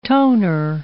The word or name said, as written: toner